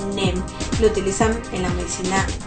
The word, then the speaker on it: neem: Y esta fruta es utilizada en la medicina ayurvédica, se escribe N-E-E-M, NEM, ¿ok?